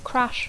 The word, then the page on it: crash: English